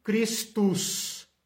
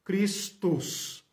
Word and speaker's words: Christus: Como se fala Cristo em latim? Christus, Christus, ok?